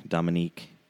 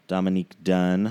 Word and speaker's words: Dominique Dunne: Do, uh, Dominic, Dominique, Dominique Dunne. I have to say her name right.